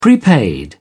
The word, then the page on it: prepaid: British English